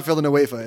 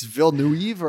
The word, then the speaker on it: Villeneuve: It's Denis but his last name is not Villanueva. It's Villeneuve or I don't know, whatever.